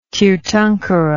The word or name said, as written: tirthankara